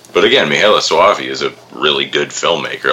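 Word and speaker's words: Michele Soavi: Well, it kind of looked like, other than the Luigi Cozzi, it mostly stayed between Lamberto Bava and how do you say his name? Michele Sova? – Mihaela Soavi. – Yeah, I always say it wrong. I'm never going to be able to say it right.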